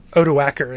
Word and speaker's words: Odoacer: Odoacer. It's a hard C in Latin, right? – Yeah.